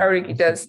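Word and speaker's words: Oruguitas: But I think we're only going to see, I don't know, I think those Orguitas, how do you say it? I'm just killing Orguitas.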